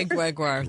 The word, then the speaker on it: Gregoire: Oh, Sheila Ray Gregoire.